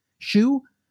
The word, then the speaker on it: Xu: It's XU. How do you pronounce that? Shu? Or is that Zhu? – That works.